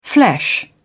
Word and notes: flesh: British English

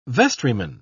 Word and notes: vestryman: British English

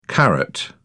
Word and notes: caret: British English

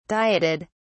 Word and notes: dieted: British English

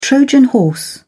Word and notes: trojan horse: British English

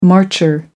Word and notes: marcher: American English

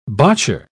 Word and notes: botcher: British English

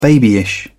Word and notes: babyish: British English

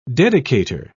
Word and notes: dedicator: British English

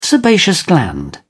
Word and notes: sebaceous gland: British English

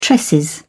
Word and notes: tresses: British English

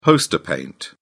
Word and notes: poster paint: British English

